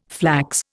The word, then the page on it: flax: Indian